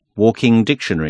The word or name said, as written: levande lexikon